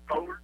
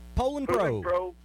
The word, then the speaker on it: Poulan: But this morning, I still have that Poland. I'm saying it wrong. – Poland Pro 20-inch chainsaw.